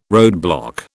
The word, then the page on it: roadblock: British English